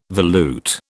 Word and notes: volute: British English

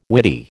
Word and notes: witty: American English

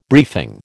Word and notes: briefing: American English

